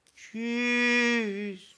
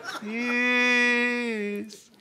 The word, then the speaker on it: Tschüss: Und das Wichtigste an Tschüss, das wissen Sie am besten, ist die Betonung, die Terz. Tschüss. Tschüss.